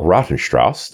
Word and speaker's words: Rautenstrauch: That's Karl Rautenstrauch.